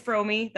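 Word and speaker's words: Fromme: It is Frome. That's how she pronounces it.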